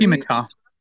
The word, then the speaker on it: Qumeka: Kumika. – Okay. – Kumika, her guide. – An ascended master, basically. – Lord Kumika. His name is spelled Q-U-M-E-K-A.